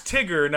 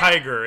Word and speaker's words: Tigger: Well, his non- name's Tigger, not yeah. Tiger.